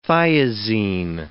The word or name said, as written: thiazine